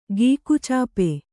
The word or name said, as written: ಗೀಕುಚಾಪೆ